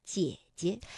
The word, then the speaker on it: 姐姐: 但是当连读的时候，第一个姐呢，我们就把它读成二幺，姐姐。